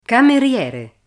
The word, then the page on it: cameriere: Italian